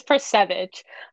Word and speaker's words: Percevecz: It's Percevecz. I think it looks like the way you said it.